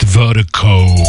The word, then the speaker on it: Virdiko: And Vertico is spelled V-I-R-D-I-K-O. That's V-I-R-D-I-K-O, pronounced Vertico.